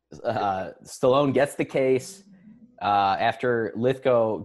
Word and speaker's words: Lithgow: Lithgow, yeah.